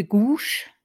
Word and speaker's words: Gouges: Heute geht es um eine ganz wichtige Persönlichkeit, um Olympe de Gauche. De Gouche, so spricht man sie eigentlich aus.